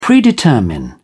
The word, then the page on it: predetermine: British English